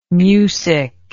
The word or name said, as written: mucic